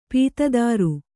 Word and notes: ಪತ್ತೆದಾರ: pattedāra

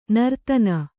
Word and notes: ನರ್ತನ: nartana

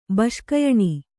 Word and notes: ಬೆಸುಗೆಯಾನ್: Kannada

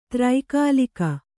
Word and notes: ತ್ರೈಕಾಲಿಕ: Kannada

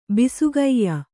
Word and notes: ಬಿಸುಗಯ್ಯ: Kannada

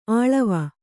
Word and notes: ಅಳ್ವು: aḷvu

